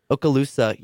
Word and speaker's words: Okaloosa: Okaloosa? – Yeah. Okaloosa, yeah, I'll just say that. Okaloosa